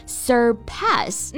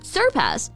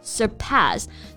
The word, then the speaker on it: surpass: 对，然后这个单词的发音大家要注意，重音呢是在第二个音节 surpass。 – 嗯，不要读成 surpass， no， surpass。